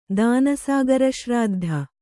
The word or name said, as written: ದಾನಸಾಗರಶ್ರಾದ್ಧ